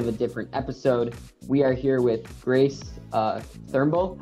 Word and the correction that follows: Trumbull: How do you pronounce your last name? Sorry, I'm already butchering it in the beginning of the podcast. – It's Trumbull. – Trumbull.